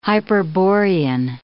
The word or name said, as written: hyperborean